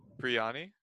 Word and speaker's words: biryani: Biryani? – Oh, you pronounce it right.